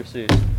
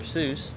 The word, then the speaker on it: Seuss: And by the way, did you know the actual German pronunciation of Seuss rhymes with voice? Like, it should be Dr. Seuss. – Like soy sauce? – Like soy sauce. Yeah, sure. But the American pronunciation, it sounds more like juice.